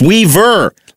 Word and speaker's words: Weaver: People think I am saying my name with a B, like B as in Brian. So Weber. No, it's Weaver.